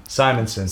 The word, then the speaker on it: Simonson: Walt how do you say his name? – Simonson. – Simonson, like, yeah.